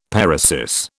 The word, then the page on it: paresis: British English